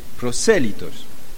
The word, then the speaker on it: prosélitos: Y también había proselitos, hombres que no eran judíos, pero que escuchaban el Evangelio. Prosélitos. a prosélitos, sí. Sí, pronunciación de español y no del francés.